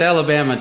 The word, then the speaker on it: Alabama: And do you pronounce it Alabama or Alabama, or how do you say it? – It's Alabama to me, yeah.